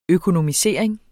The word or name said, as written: økometer